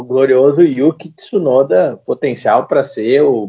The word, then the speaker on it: Tsunoda: E tem uma possibilidade de daqui a pouco o Tsunda apareceu, acho que é essa pronúncia, sou péssima em pronúncia Tsunoda. asiática. Tsunoda, Tsunoda. Isso.